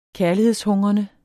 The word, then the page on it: kærlighedshungrende: -ˌhɔŋʁʌnə; -ˌhɔŋˀʁʌnə